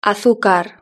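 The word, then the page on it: azucar: European Spanish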